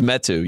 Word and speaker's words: Metu: It's Metu, yes.